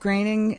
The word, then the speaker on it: Groening: And Matt Groening is letting this happen? – Apparently. I don't know. – That is how you say the name, by the way.